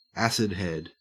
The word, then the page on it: acidhead: Australian English